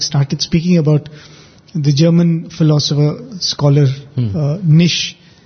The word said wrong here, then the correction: Nietzsche: I hope I'm pronouncing that name right. – Nietzsche.